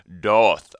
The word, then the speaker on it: Daath: I like to end the show with a cover, as I always do, and this cover is coming from Doth. I think that's how you pronounce it. It's spelled D-A-A-T-H.